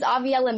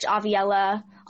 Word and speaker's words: Aviella: Yeah, everything is Aviella Music on my socials. So Instagram, TikTok, and then you can just search Aviella on Google, whatever pulls up.